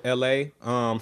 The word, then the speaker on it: Elhae: Yes, it's spelled ELHAE. It's funny that his name is LA, but he has a song out with somebody called I Am.